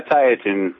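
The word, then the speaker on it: Tieton: And anyhow, Teton, Washington. Am I saying it right? – Tyotin. – Tyotin.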